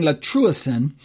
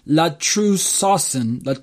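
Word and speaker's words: latrusasen (λατρεύσουσιν): I really butchered that word last week. I kept saying latrusen, but it's latrusasen. So I dropped off one of the extra S's. I apologize. Latrusasen.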